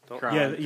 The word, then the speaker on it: Quran: It's actually going to be Harry Potter and the Quran. – And the what? – And the Quran? – And the Quran? – Quran. – Quran. – Yes, for Americans, it's the Quran.